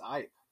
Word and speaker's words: ipe: Hey, Ross, also, I think you're mispronouncing it. I think it's Ipe.